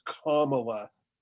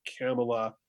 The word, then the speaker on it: Kamala: So now Kamala Harris, oh, by the way, I should warn everybody, make sure you pronounce the name correctly. It's Kamala. – Oh, my goodness. – Tucker Carlson got into an awful lot of trouble for pronouncing it Kamala.